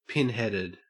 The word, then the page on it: pinheaded: Australian English